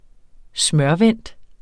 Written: smørvendt

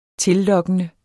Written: tillokkende

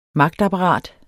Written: magtapparat

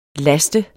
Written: last

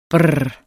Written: brrr

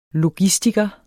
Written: logistiker

